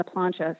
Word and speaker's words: plancha: And then talk a little bit about the plancha bread. Is that how you pronounce it? Okay, yeah. Yes, plancha bread.